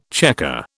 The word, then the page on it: chequer: British English